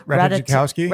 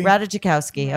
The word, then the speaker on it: Ratajkowski: Ratajkowski? – Ratajkowski.